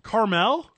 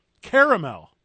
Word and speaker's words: caramel: Caramel. Are you messing with me? – Where's that extra letter? – What? How do you believe that caramel is spelled? – C-A-R-M-E-L. – There's an A. Caramel.